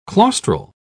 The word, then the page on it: claustral: British English